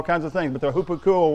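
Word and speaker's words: Hupakuo (ὑπακούω): Virtually every time he talks about obeying the gospel, every time I found, he uses the Greek word hupakuo. It's spelled H-U-P-A-K-U-O. That's how you transliterate it so you can pronounce it in English. Hupakuo.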